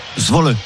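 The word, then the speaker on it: Zwolle: Comment tu prononces le club néerlandais? Zwol. – Zwol. – Zwol. Zwol, on prononce le. Très bien.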